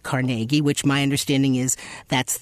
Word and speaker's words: Carnegie: So, of course, I had to put in both pronouncers because the hall does indeed call itself Carnegie, and that's yes.